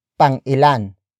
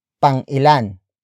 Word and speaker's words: Pang-ilan: Pang-ilan. Pang-ilan.